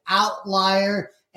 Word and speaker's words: OVTLIER: Yeah, I did say it right. It does have a V in it, but it is OVTLIER.